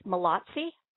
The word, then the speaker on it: Mallozzi: And your name was pronounced Mallozzi? – Yep, that's right.